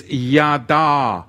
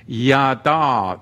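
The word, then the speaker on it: Yada (ידע): Da'at, it's spelt Dalet Ayin Tav, and it is a covenant term, whereas the other Hebrew word to know is Yada. It is Yod Dalet Ayin. Yada.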